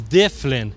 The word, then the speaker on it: Dyflinn: Getting back to our third settlement, Dublin, the Vikings' pronunciation of Dublin is Diflin, which is D-Y-F-L-I-N-N. They couldn't pronounce Dublin, so it becomes Diflin.